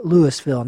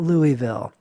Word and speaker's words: Louisville: And yeah, it's purposely pronounced Louisville, not Louisville.